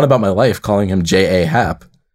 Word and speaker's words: J.A. Happ: he pronounces his name as J.A.Y., so J. – So it is J.Happ. – Yeah, it's J.Happ.